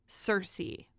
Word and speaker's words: Circe: Number two, Cersei by Madeline Miller. It is not spelled like Game of Thrones Cersei. It's spelled C-I-R-C-E, which I assume the Game of Thrones Cersei is adapted from, because Cersei is a Greek, she's a mythological Greek goddess.